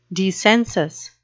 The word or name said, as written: dissensus